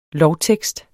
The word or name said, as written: lovtekst